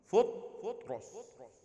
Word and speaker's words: فطرس: فطرس فطرس فطرس اي واحدة فيهم فطرس هذا النطق الصحيح ضم وسكون فطرس